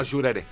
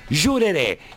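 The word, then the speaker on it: Jurerê: Porque ayer se conoció, el 13 de enero, en Florianópolis, no sé cómo se pronuncia, ¿es Jureré o Jureré? – No, Jureré. – Jureré.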